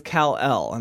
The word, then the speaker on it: Kal-El: His name is Kal-El, and so mine's Kal-Lal, so we're close.